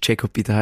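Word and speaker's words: Jacob Peter: Genau, und das hat auch den Hintergrund, dass ich eigentlich Jakob Peter heiße, das wissen wenige, aber man spricht es eigentlich Englisch aus. Jakob, beziehungsweise Jakob schreibt man mit C und deshalb auch JP, Jakob so.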